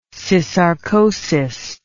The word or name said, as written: syssarcosis